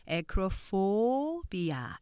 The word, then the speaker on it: acrophobia: 음절 표시 보시면 a crow 이음절 for be 아 이렇게 오음절로 발음돼요. 어려워 보이시지만 여러분이 알고 계시는 어, university 오음절과 같은 소리고 보통 오음절은 세 번째 액센트가 있는 경우가 많아요. 여기도 acrophobia 이렇게 발음하시면 되는 거죠.